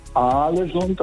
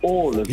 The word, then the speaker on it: Ålesund: Also das heißt nicht Alesund, das heißt Ålesund.